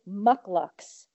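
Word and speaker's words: Muk Luks: Mucklucks, and it's spelled M-U-K, next word, L-U-K-S, Mucklucks.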